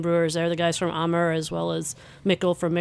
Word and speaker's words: Amager: Amager. That's how you say it. A-M-A-G-E-R.